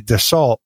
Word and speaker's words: Dassault: I used to call it Dassault, like, you know, what I would say at the dinner table, pass me Dassault. But I was working in flight safety, and I ran into some pilots who used to fly that brand, and they told me that it's pronounced Dassault. You can't even hear the L, but definitely the T is silent, like the P in swimming.